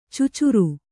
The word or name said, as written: ಚುಚುರು